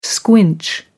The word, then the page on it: squinch: American English